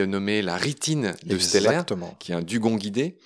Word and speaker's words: Steller: Oui, Bering embarquait un, naturaliste qui s'appelait Steller, et Steller a été l'un de ceux à l'époque qui, le premier, a émis cette hypothèse. – Oui, alors tu l'appelles Steller, c'est bien, tu prononces bien les mots allemands.